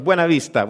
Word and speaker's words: Buena Vista: And I'm not going to say it like the locals say it. They say something else, but I say Buena Vista because I speak Spanish. So it's Buena Vista, which means good view, right?